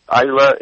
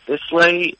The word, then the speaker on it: Islay: it's from the island of Islay, Islay, how do you pronounce it? And I always like to say, just remember, it means the island of Ireland, and then you've got the pronunciation of the region.